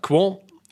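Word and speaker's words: Qwant: Sen så har vi också Europaparlamentet, och det där var ganska kul, för de har nyss bytt ut Google mot franska Quant, Jag var faktiskt tvungen att fråga äldsta sonen hur man skulle uttala det där, men det ska vara Quan.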